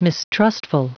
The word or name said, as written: mistrustful